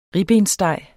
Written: ribbenssteg

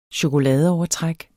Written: chokoladeovertræk

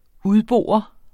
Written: udbore

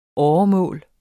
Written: åremål

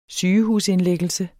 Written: sygehusindlæggelse

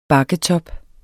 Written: bakketop

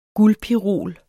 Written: guldpirol

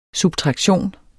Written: SUBTRAKTION

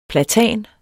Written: PLATAN

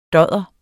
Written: dodder

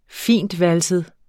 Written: fintvalset

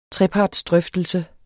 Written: trepartsdrøftelse

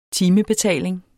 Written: timebetaling

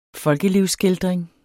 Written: folkelivsskildring

